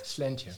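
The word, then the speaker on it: Sláinte: Oh, hoe zei je dat? Slentje. Slentje. Toch? Zo spreek je dat uit.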